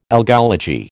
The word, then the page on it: algology: American English